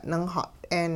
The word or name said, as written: ningxat'een